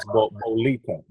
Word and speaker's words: Bolita: And that's Bolita. That's how I pronounce it, Bolita.